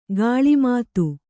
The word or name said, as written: ಗಿಳಿಮಾತು